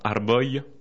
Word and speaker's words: arboj: On rajoute simplement un « j » qui se prononce « ye ». Par exemple, « arbo » un arbre, « arboy » c'est des arbres. Hein. Et ça s'écrit avec un « j ». A-R-B-O-J.